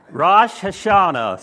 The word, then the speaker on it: Rosh Hashanah: I looked it up, and that's the pronunciation. Rosh Hashanah.